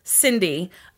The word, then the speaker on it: Syndi: Her parents and her sister, Sydney, I'm sorry, Cindy. Okay, let me just preface this by saying this girl's name is spelled S-Y-N-D-I.